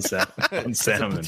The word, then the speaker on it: salmon: This guy goes full L on salmon. He's catching me with it now. It's contagious. – Salmon. You want to pronounce the letters? Pronounce them.